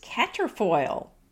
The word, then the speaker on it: quatrefoil: It is caterfoil. – Say it again. – Caterfoil. I'll spell it Q-U-A-T-R-E-F-O-I-L. So you'll want to say quatrefoil, but it's really caterfoil.